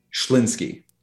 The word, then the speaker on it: Schlinsky: How do you say your last name? – Schlinsky. – Schlinsky? – Schlinsky. – So just like it's spelled. Awesome. – Yep. Four consonants in a row. Pretty strange. S-C-H-L.